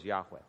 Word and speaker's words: Yahweh: But the correct pronunciation almost for sure is Yahweh.